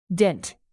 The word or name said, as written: dint